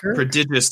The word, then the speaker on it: prodigious: Prodigious strength. – Prodigious strength. – Prodigious. – Prodigious, bruh. – Prodigious? Prodigious? – It's prodigious. It's prodigious. That's how it's pronounced.